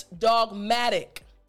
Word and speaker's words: dogmatic: So instead of dogmatic, it's dogmatic. Good, again, that T has a D sound.